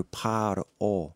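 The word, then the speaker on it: par-o (פרעה): When you look at the word for Pharaoh in the book of Exodus, it's pronounced in the Hebrew par-o.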